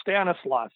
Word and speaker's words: Stanislaus: Harley had gained a lot of his early wrestling training from the Zbyszko brothers, and I can never pronounce their first names. Uh, but you know who I you know who Stanislaus I Stanislaus and Vladek. – Yeah, there you go, Stanislaus.